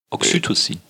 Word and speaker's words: Oxytocin: Was nicht umstritten ist, ist, dass, oh mein Gott, kannst du es aussprechen? – Oxytocin? – Ja. – Oxytocin. – Oxytocin.